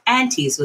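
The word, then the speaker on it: Antes: And with that, aunties was born. And I want to spell that for you guys. It's A-N-T-E-S. Pronounced aunties, like aunt and uncle.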